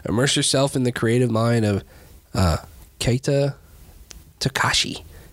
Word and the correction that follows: Takahashi: Takahashi. – Sorry. – Come on. – I missed a syllable.